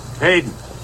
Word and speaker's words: Paden: Payton. – P-A-D-E-N.